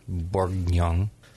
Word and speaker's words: Bourguignon: Bourguignon. – I used to call it Bourguignon Blanc, but I was counseled by a Frenchman that you pronounce the first G, apparently.